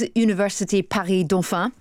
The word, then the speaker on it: Université Paris-Dauphine: The city's Université Paris-Dauphine, did you like that pronunciation? – That was very good.